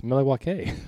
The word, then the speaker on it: Milwaukee: He's like, he goes, actually, it's pronounced Milwaukee, which means something of the land in Algonquin.